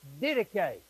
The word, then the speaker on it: Didache: And when we get a newsletter from international church planters, it says on it this spelling, D-I-D-A-C-H-E. Okay? It's that institution. That word is pronounced didache. It's really easy.